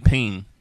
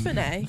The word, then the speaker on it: penne: Apparently it's like pain. – Pain? No, it's penne. – It's penne. – It's penne.